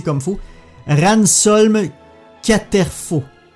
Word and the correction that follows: Casterfo: Casterfo, qui est un jeune politicien qui a des idéaux diamétralement opposés à ceux de Léa.